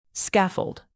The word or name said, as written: scaffold